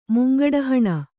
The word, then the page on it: ಮುಂಗಡಹಣ: Kannada